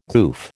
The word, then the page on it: goof: American English